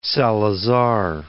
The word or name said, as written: Salazar